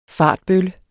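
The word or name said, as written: Fartbølle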